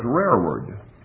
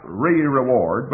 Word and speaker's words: rearward: Well, unfortunately, that word's rearward. It's spelled like re-reward, but it's rearward.